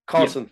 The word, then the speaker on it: Cosson: Is that right? I'm pronouncing that correct? – Cosson. – Yeah? Cosson. Yeah. Sorry about that.